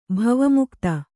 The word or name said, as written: ಭವಮುಕ್ತ